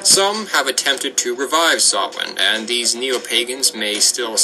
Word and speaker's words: Samhain: It's spelled S-A-M-H-A-I-N, but because Irish is a confusing language, it's pronounced Samhain.